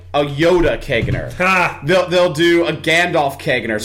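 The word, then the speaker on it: caganer: I believe it's pronounced a Kegner. Kegner.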